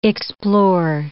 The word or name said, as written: explore